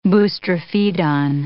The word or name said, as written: boustrophedon